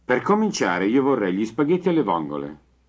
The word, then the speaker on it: spaghetti: Per cominciare, io vorrei gli spaghetti alle vongole. – Repeat. – Gli spaghetti. Gli spaghetti. – Be sure to pronounce Spaghetti. Spaghetti. – with two T's.